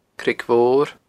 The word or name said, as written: Creag Mhor